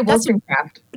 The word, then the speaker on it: Wollstonecraft: It's Wollstonecraft? – I say Wollstonecraft.